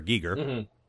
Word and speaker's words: Giger: The whole reason I thought it was a reference to H.R. Geiger, which we now know is pronounced H.R. Giger.